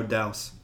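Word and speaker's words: Dowse: I pulled up the director, Michael Dowsey. – Mm-hmm. – I think I pronounced that right. No, Douse.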